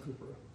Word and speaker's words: Cowper: And it's spelled Cowper, but it's pronounced Cooper.